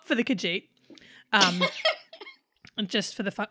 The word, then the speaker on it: Khajiit: How do you say the Elder Scrolls race? The Khajiit? – Khajiit, yeah. – Khajiit.